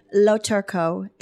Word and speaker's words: Loturco: Loturco.